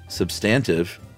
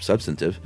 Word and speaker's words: substantive: Wade episode, Chuck at first pronounced substantive with the accent on the second syllable, and instead of substantive.